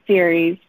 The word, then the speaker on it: Ceres: but how do you pronounce it? – Yeah, Ceres.